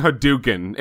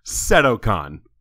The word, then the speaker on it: Sadokan: Sadokan, perhaps, is his act? Yeah, I'm like saying Hadouken. It's Sadokan.